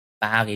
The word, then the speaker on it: Bari: Du sagst immer Paris. – Paris, aber dann habe ich jeder gefragt, ob ich nach Paris fliegen. Nein, wir waren nicht. – Urlaub in Paris. – Das stimmt. Paris in Italien. – Bari, Entschuldigung, das ist falsch ausgesprochen. – Bari in Italien.